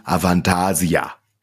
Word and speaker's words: Avantasia: Aventasia. Habe ich das jetzt richtig gesagt? – Ja, Damit klar. würde sagen Avantasia. So. – Ja, das, ist richtig.